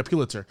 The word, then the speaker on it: Pulitzer: I don't know how to pronounce Pulitzer. – It. Yeah, Pulitzer. That's what journalists get.